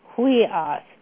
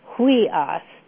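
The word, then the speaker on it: huios (υἱός): In Greek, it's huios. It's pronounced like W-H-E-E hyphen O-S, huios.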